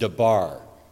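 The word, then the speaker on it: dabar (דבר): So in Hebrew, that is pronounced dabar.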